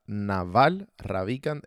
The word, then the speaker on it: Naval Ravikant: Se pronuncia Naval Ravikant, R-A-V-I-K-A-N-T.